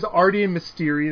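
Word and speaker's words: Arti e Mestieri: Their name is Arti and Mysteri. The first word is Arti, A-R-T-I, space, the letter E, space, and then M-E-S-T-I-E-R-I.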